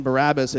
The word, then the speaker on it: Barabbas: So the real pronunciation of this man, Barabbas, it's actually two words, like Bar and Abbas.